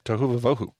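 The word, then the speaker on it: tohu va-vohu: Tohu vavohu. – Tohu vavohu. – Are we saying that right? – We are. – Okay. – Good work. Tohu vavohu.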